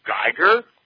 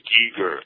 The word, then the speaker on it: Giger: I go, "Geiger?" I totally mispronounced his name. He's like, "It's Giger."